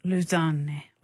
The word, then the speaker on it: Lausanne: Lausanne, which I believe is the correct French pronunciation for that ensemble.